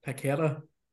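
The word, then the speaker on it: Paquetá: And your boy, hopefully I pronounced his name right, is it Paqueta? – Yeah.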